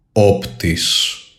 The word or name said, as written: OPTIS